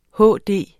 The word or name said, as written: HD